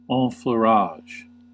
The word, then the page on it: enfleurage: American English